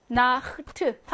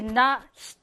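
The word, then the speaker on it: Nacht: 여러분이 실제로 발음을 해봐. 나흐트라고 발음해야 편하지. 나흐트. 다 이쪽에서 나야 편하지. 나히트. 하면은 갑자기 나 히, 이렇게 뒤로 갔다 앞으로 왔다. 이게 불편하잖아요. 그래서 나히트가 아니라 나흐트가 되는 거예요. 발음을 편하게 해주려고.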